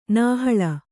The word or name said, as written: ನಾಹಳ